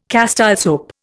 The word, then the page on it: Castile soap: Indian